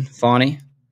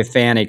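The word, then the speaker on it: Fani: Riveted to the So are you not accepting the correct pronunciation, Fawny? – Oh, it's Fawny. I forgot.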